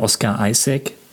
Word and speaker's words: Oscar Isaac: Auch hier der Oscar Isaac, wird der so ausgesprochen? – Würde ich auch so machen, ja.